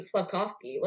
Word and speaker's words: Slavkovsky: Slavkovsky, let's talk about him. That is how you say his name, by the way. I learned that.